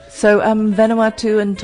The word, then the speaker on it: Vanuatu: Vanuatu.